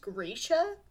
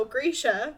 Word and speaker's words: Grisha: Also, I just remembered that it's actually pronounced Grisha. Somebody corrected me on that once, and I was like, I did not know this. Good to know. Grisha.